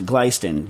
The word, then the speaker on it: Glestain: It's spelled Glestain, but I believe it's pronounced Gleiston.